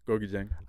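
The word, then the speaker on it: Gorgui Dieng: Gorgie Dieng.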